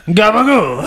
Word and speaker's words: gabagool: It's cabagool? Like, I don't know. – I mean, it's phonetically pronounced capicola, and it's turned into gabagool.